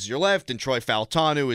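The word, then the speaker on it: Fautanu: Your starting offensive line this upcoming season could be Troy Faltanu. That's how you say his name, by the way. I got Faltanu. – Okay. – Because they released the pronunciation from his mouth.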